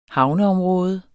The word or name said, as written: havneområde